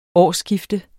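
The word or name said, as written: årsskifte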